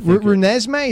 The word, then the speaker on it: Renesmee: The Twilight baby, by the way, is named Renee Sime, and or whatever. That's the character from Twilight. That's not even the real... – Renesmee, is that what it is? – I'm looking at the pronunciation.